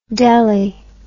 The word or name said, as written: delly